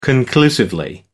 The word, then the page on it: conclusively: American English